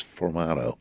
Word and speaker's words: sformato: He sent me out a sformato, which is sformato, S-F-M-O-R. It's like three consonants in a row, so it's hard to pronounce, sformato.